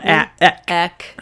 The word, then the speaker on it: Eck: E-C-K. Yep. That's it. Yep. That's it. It's more of an utterance. It's more of a syllable.